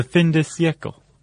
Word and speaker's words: fin de siècle: came from a left-wing Quaker intellectual at the turn of the last century, the Fin de Siècle. – Yeah. – It was, I think I'm saying that right. – Yeah, sure. – Okay. – I think it sounds right.